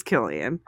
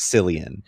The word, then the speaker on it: Cillian: Cillian, Nick. It's Cillian. – I thought, my entire life, I thought it was Cillian.